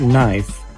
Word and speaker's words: knife: In the word knife, the K is silent. In the word honest, the H is silent.